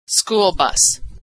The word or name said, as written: school bus